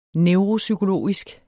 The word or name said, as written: neuropsykologisk